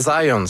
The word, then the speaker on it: Zajonc: Non, c'est Zajonc. – Oui? OK. – Ouais. J'ai-tu pas dit Zajonc? – Ben, vous avez dit plus Zajonc, mais... – Ah, ben, j'ai prononcé un petit peu à l'anglais. J'ai prononcé mon polonais à l'anglais.